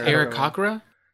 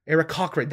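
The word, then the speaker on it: Aarakocra: I call them Arakoas. Yeah. I think they're called Arakros? Arakros? – I think it's Arakokra. – Arakokra, that's what it was.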